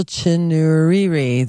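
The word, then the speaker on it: Chinouriri: Rachel Chinunuri, if I can say it right, Rachel Chinuriri, there we go.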